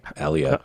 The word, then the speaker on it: Aaliyah: You said Aaliyah weird. – Yeah, Aaliyah.